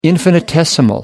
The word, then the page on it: infinitesimal: American English